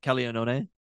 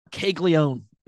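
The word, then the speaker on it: Caglianone: That's how you'd pronounce his name, right? Jack Calianone? – Yes. – It is kind of funny when you see the American version of it where it's like Caglione. And you're like, it's not really that close.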